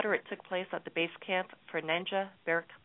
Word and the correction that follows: Nanga Parbat: Peak. And I better say that properly. Nanga Parbat Peak. My apologies.